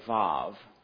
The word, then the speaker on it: Vav: If you a Hebrew today, they would say Vav in the Hebrew today. The V is how they would pronounce it. But in the biblical times, it was a Wah.